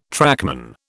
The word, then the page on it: trackman: British English